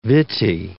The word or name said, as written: vittae